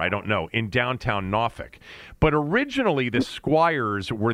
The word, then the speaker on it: Norfolk: They played in Norfolk, Virginia, which is exactly the way it's pronounced by people who are from down there. It's not Norfolk. It's not Norfolk. It's Norfolk.